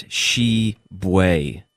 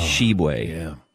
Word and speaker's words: Tshiebwe: The T-S-H... – His name is Shibway. – Shibway. I'm guessing I'm missing a B. – T-S-H-I-E-B-W-E. – Oh. – Shibway.